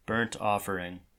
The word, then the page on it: burnt offering: American English; GA; IPA: /bɝnt ˈɔfəɹɪŋ/; IPA: /-ˈɑfəɹɪŋ/